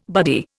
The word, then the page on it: buddy: Indian